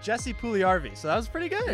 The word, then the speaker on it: Jesse Puljujärvi: Jesse Puljujärvi is the last name.